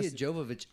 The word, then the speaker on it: Jovovich: Mia Jovovich almost acted in that film. – Wow. Almost. Almost. You almost said her name right. – Mila? – Well, yeah, it's Jovovich.